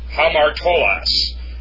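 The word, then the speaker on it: Hamartolos (ἁμαρτωλός): Amartolos, actually it's a rough breathing, pardon me. It's Hamartolos, that's a sinner.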